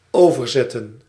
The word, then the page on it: ferry: /ˈfɛɹ.i/